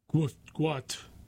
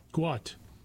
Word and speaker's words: Gout: In 2005, Bonna, B-O-N-A, and Monica Gout, pronounced Gwout. It's Gout, spelled G-O-U-T, is pronounced G-W-O-T, Gwout, sought to leave war-ravaged South Sudan.